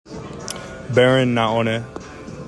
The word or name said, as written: BARON NAONE